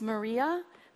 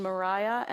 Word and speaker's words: Maria: So Maria Mitchell, her name looks like it's Maria, but it's the English pronunciation Mariah, and most people, if they've even heard of her, do not know that.